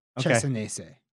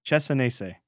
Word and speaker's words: Cesanese: C-E-S-A-N-E-S-E. Chesanese. – Chesanese.